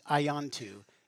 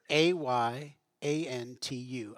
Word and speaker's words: Ayantu: I noticed her name, and her name was, I think it would be pronounced Ayantu, A-Y. A-N-T-U, Ayantu.